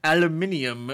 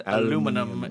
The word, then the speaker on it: aluminium: Next element is aluminium. If you're from across the pond, aluminum. If you live here.